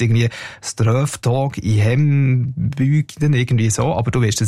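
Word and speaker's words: Strövtåg i hembygden: Ich finde, du hast die Aussprache super hergebracht. Das Lied heisst Ströftag in Hembygden, genau.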